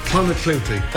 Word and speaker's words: Clonakilty: Irish tea bags or Clonakilty sausages from Cork. – Clonakilty, thank you.